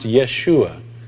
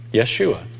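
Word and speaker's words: Yeshua (ישוע): Interesting that Joshua in Hebrew is pronounced Yeshua. Guess how Jesus is pronounced in Hebrew? It's the same. Yeshua is Jesus.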